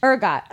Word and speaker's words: ergot: Ergot.